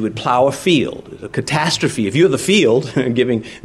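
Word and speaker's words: catastrophe (καταστροφή): Now, ruin here in the Greek is catastrophe. It is pronounced almost just like that.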